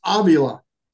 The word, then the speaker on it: Avila: But I learned how to say this name. It's Avila.